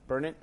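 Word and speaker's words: Burnet: How do you say that road? – Burnett. – I said Burnett. – Like Carol.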